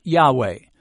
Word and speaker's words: Yahweh: It is simply four consonants put together, which we typically pronounce Yahweh. Some have called this name Jehovah, but Yahweh is probably closer to the Hebrew pronunciation.